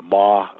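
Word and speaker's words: Ma (麻): and he did include Ma. That's spelled M-A, which is the Chinese pronunciation for the word for cannabis, and said that it was useful for treating pain and a number of other conditions.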